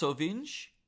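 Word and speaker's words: Rovinj: And I believe it's pronounced Sovinj. It's Sovinje in Croatia.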